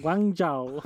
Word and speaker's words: Guangzhou: Gang, Guangzhou. Guangzhou. Guangzhou.